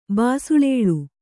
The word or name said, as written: ಬಾಸುಳೇಳು